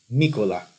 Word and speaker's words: Mykola: Well, actually, Mykola Nikolaevich Blinov, which is the Ukrainian pronunciation of his name.